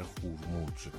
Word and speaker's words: خورموج: محمد هستم از خورموج بندر بوشهر، امیدوارم درست تلفظ کرده باشم، بندر خورموج. درود بر شما. درست گفتم استاد؟ – خورموج. – خورموج.